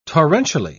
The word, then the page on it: torrentially: British English